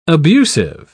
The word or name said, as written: abusive